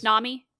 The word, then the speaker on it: Nami: Is that how you say her name? The navigator? – Yes. – The pirate thief, yes. Nami? Yeah.